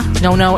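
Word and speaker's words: Nonno: This one's for you, Nona and Nona. Nono and Nona. Keep saying that incorrectly.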